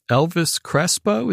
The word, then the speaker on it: Elvis Crespo: Let's do your second song, which is Elvis Crespo.